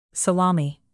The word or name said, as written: salami